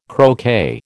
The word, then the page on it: croquet: American English